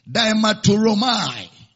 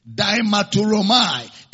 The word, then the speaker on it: diamarturomai: That is a speaking action, or the word, take the Greek word, diamaturomai. Diamaturomai is spelled as D-I-A-M-A-R-T-U-R-O-M-A-I.